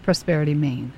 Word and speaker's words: Prosperity ME: Yes, it's written Prosperity ME, but pronounced Prosperity Maine.